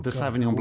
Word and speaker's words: Sauvignon: The Académie Française, not terribly happy about your mispronunciation of Sauvignon. You kind of said Sauvignon. Sauvignon Blanc from Marlborough, New Zealand.